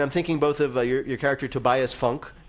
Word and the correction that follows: Funke: Am I saying that right? Funke? That is? In Arrested Development? – It is Funke, yeah.